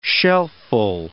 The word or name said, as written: shelfful